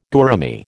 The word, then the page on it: gourami: American English